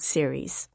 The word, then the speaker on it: Ceres: rocky dwarf planet called Ceres. – In English it's Ceres, in Italian it's Ceres, because it is, was discovered by an Italian astronomer.